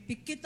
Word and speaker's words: Pikit: I was going to say, kanya siguro madali. May pikit ang mata. Pikit.